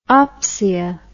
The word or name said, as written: opsia